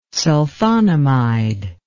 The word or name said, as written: sulfonamide